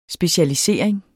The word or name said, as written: specialisering